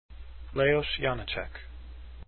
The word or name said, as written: janacek